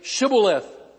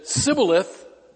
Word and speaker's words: Shibboleth: If he replied, "No," they said, "All right, say Shibboleth." If he said Sibboleth, because he could not pronounce the word correctly, they seized him and killed him at the fords of the Jordan.